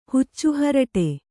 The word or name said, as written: ಹುಚ್ಚುಹರಟೆ